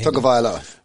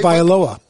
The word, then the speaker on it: Tagovailoa: How do you say it? Hang on, let's compare this. What did Tagovailoa. – You say? – Tagovailoa. – Tagovailoa, isn't it? Tagovailoa. – You're just cutting the A, that's all.